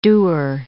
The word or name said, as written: dewar